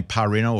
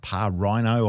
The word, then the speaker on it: Parrino: I think you say his name Parrino or Parrino. Or it's P-A-R-R-I-N-O, of course.